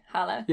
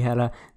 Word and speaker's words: Halla: Yeah, I don't know how her name is spelled in the books, but in the audiobook, they pronounce her name like Hala. – Yeah, it's like H-A-L-L-A, Hala. – Yeah, I think it's supposed to be Hala.